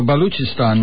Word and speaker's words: Baluchistan: Was it Balustan? – Oh, no, Baluchistan, Maya.